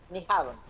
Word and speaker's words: Nyhavn: Nyhavn, así se pronuncia.